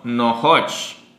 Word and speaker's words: nohoch: Nonosh, grande. Nonosh, no, nohoch. Es que es una H.